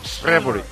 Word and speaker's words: Shrewsbury: A las 6 de la tarde, Shrewsbury, Liverpool. En, sí, algo así. Es que es S-H-R-E. ¿Cómo pronuncias? Shrewsbury.